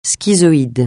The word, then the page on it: schizoïde: francais